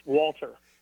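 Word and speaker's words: Gwalter: Actually, it was only disclosed in one episode, and the G is actually silent. His middle name is Walter. – Wait, what? – I believe it's Welsh. It's G-W-A-L-T-E-R, but it's pronounced Walter.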